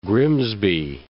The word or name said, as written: Grimsby